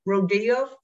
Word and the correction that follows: Rodel: Is that the name of the company, if I'm saying that right? – Rodel. – Yeah, Rodel.